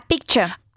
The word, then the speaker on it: picture: A picture. – A picture. ¿Y qué bien has hecho eso de la pronunciación de picture? Que es con una cha final.